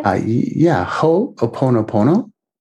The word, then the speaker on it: Ho'oponopono: Ho'oponopono. I'll have to spell it for you. It's H-O apostrophe. O-P-O-N-O, P-O-N-O.